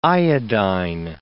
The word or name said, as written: iodine